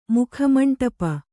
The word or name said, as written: ಮುಖಮಂಡಪ